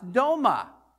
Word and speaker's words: doma: The second word in that is pronounced doma. Doma, that sounds like kind of like dome.